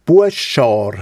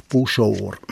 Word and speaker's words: Buschor: Den Oldi Hammer legt Wert darauf, dass die traditionelle Mundartaussprache von Buschor zu Altstädten Buschar sei. Aber das wüsste der Zaltstädter nur noch die wenigsten. Die meisten sagten da dort heute halt nach der Schrift einfach Buschor.